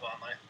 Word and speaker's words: Vonleh: They sent Gerald Henderson and Noah Vonleh. I hope I'm saying that right. – Yeah, Noah Vonleh.